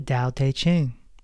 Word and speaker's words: Tao Te Ching: Book number 5, speaking of Lao Tzu, it's the Tao Te Ching. For those that are not familiar, it is spelled T-A-O-T-E-C-H-I-N-G. In Chinese, T's are pronounced D.